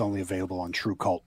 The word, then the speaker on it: TRVEKVLT: And just to clarify for anybody listening who wasn't watching earlier, it's spelled TRVEKVLT, but pronounced True Cult, right?